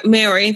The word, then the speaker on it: Maori: But Te Hamua Nakora, a Maori living in, I could have definitely pronounced that wrong. – Maori, thank you.